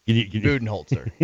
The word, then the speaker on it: Budenholzer: It's Mike Buttenhauser, Buttenbooger, or whatever. I can never pronounce it right. – No, that's why we talk NBA on the show. – Budenholzer. Yeah, Budenholzer. It's something like that. I was close.